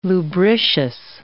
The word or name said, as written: lubricious